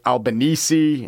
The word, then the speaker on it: Albanese: They sound like that guy sounded, and that guy's name is pronounced Anthony Albanese instead of Anthony Albanese, like seven guys I went to high school with.